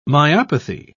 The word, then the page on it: myopathy: British English